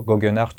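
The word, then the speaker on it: Geoghegan Hart: T'as euh, Tao euh, Goggenhardt, je sais pas si je le dis bien, c'est toujours compliqué. Non, ça va, c'est bien.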